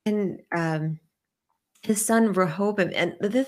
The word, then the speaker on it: Rehoboam: And you see that in the book of 1 Kings chapter 11, and when basically Rehoboam, if I'm saying his name right, his Rehoboam. – Son comes, Rehoboam, thank you, comes in and basically the people ask him, please, make our taxes, lighten our load, lighten our taxes.